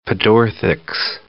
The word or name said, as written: pedorthics